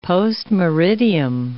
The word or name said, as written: post meridiem